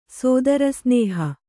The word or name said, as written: ಸೋದರಸ್ನೇಹ